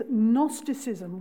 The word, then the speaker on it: Gnosticism: The second big word is Gnosticism, which had a silent G at the front. So it's G-N-O-S-T-I-C-I-S-M, Gnosticism. From the Greek word gnosis, G-N-O-S-I-S.